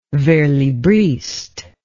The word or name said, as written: verslibriste